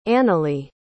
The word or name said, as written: annily